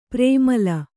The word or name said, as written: ಪ್ರೇಮಲೆ